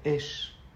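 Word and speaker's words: Esh (אש): Se dice Esh. Esh se escribe Aleph Shin. Aleph es porque la Torah te hace grande, te hace un Ish, te hace una Isha.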